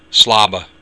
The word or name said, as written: slaba